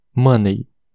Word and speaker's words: money: É, regra 5, quando o Y ou e y terminarem uma palavra e a sílaba final não for a forte, o y vai ter som de e. Exemplo: money. Uh, ah, M-O-N-E-Y. Money. É, no caso, é o E do inglês, tá bom?